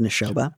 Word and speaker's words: Neshoba: Since we have been together, he has asked me to attend a large family function at the Neshoba, I think it's pronounced Neshoba, mm-hmm.